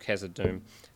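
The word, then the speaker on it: Khazad-dûm: And I think about, it's probably the first time I became aware of really good pacing was The Bridge of Khazad Dûm, is pronounced that way, the first book.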